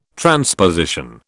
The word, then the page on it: transposition: British English